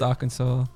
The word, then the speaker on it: Arkansas: So we've got a shout out to Kansas and Arkansas, which is pronounced Arkansas.